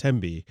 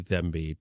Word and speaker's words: Thembi: Before that, Pharoah Sanders with, I believe it's pronounced Tembi, might be Thembi, T-H-E-M-B-I.